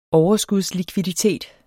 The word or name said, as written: likviditetsproblem